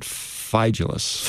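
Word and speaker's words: Phygelus: Well, I looked at my old RSV, you know, and they had the pronunciation. I had Phygellus.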